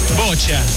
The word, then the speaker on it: boccia: En la Teletón contamos también con atletas en natación, atletismo, boccia. – Bocha. – ¿Cómo se pronuncia esto, Bocha.